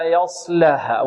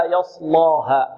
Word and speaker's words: يصلاها: فأنذرتكم نارا تلظى لا يصلاها، واللام هنا مرققة. البعض يقول لا يصلاها، يصلا، لا، لا يصلاها، لا، بدون إمالة الألف.